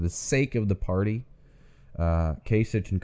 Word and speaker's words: Kasich: It's Kasich. Rhymes with basic. – Okay. Kasich.